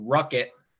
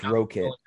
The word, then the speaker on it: Rokit: Ruckit or Rokit, however you pronounce it. – I think it's Rokit. Yeah, because it's an RO.